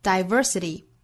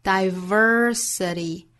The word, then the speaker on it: diversity: 那美音需要注意的就是 diversity 这个词读成 diversity。还是那句话，美音之中只要单词含有字母 r，一般会有一个缩舌的 r 的发音 diversity，其他的部分英音和美音是没有差别的。